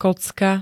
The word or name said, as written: kocka